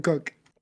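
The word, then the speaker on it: Koch: Sam Cock, the punter and holder. Justin Tucker, the kicker. Cook. And huh? – It's Sam Cook.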